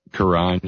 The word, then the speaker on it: Quran: Well, okay, so the Quran, am I saying that right? – Quran, yeah.